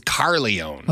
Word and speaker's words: Carlyon: I'm assuming that's how you say it, C-A-R-L-Y-O-N. Hayes Carleon. It's dramatic. I like it. – You mean from the Corleone family? – No, that's Corleone. Okay. This is Carleone.